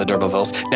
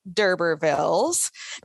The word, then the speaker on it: D'Urbervilles: About any pronunciations, but I do say D'Urbervilles. Now, of course, it's supposed to be, you know, it's a decline of a French name, which would be like D'Urberville, I guess. But it is set, the novel is set in England, so the English would not pronounce it that way.